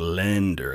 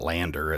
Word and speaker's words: Länder: To an English speaker, the way you can kind of mock pronounce this is put an E after that vowel. So like it's like lender. It's not lander. It's lender.